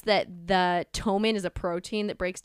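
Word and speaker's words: ptomaine: This time, he supposedly had died from something called, and I don't think I'm pronouncing this correctly, because it's spelled P-T-O-M-A-I-N-E, P-T, the little, like, consonants right next to each other. So I think it's called Tomin poisoning. I think the P is silent, but I'm okay.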